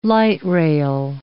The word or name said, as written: light-rail